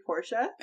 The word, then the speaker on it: Porsche: So the funny thing is that he's rich enough to have a Porsche, but he's not rich enough to pronounce it correctly. – Yeah. – It's actually Porsche.